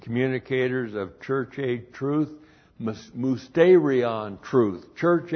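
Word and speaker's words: mousterion (μυστήριον): Looks like that in the Greek, M-U-S-T, long E-R-I-O-N, mousterion.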